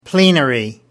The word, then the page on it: plenary: American English